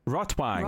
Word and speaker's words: Rotwang: It's probably a V sound. Yeah, you're right. Rotwang. Yeah. – Wang. Doesn't sound German. – Sounds like red dick. – Rotwang.